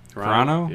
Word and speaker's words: Carano: He directed a Hunter Biden movie with Gina Carano. How you say her name? – Carano? Yeah.